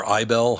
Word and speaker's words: Isbell: And as Rolling Stones reports, he helped shape the Americana genre, whose current star is Jason, is it Isbell or Ibell? How do you say his name? I think it's Isbell.